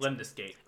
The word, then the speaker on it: Lemnis Gate: But then the other one is a game that you have to pronounce for me because I'm going to mispronounce it. Lemnis Gate. Lemnis Gate? – Lemnis Gate. Okay.